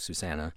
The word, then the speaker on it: Susanne: Susanna Sinfair gets actually spelled S-U-S-A-N-N-E, so it looks kind of like Suzanne, but it's pronounced Susanna. And Sinfair gets S-U-N-D-F, and then basically an O with a slash through it, R.